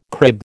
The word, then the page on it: crib: American English